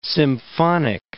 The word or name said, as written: symphonic